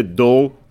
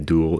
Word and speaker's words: Dool: It's Dutch, yeah. Yeah, you could say Dool. That's the Dutch way of pronouncing it. And or you could say Dool if you're English speaking.